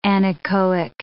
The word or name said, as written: anechoic